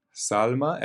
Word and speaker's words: Salma: Salma S A L M A.